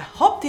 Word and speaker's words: hope: Is it hop or hope? – Hope. – Thank you. Hope.